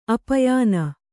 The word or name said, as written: ಆಪ್ಯಾನ